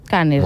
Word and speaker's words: Cannes: Com s'ha de pronunciar aquest festival? – Cannes. – Cannes, m'ho acabo d'inventar, però... – Cannes. Cannes, amb neutre.